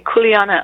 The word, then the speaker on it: kuleana: Is that how you pronounce the Hawaiian word? – Right, kuleana. Aloha, Monk, and mahalo for having me on your show. Yeah, it's kuleana works.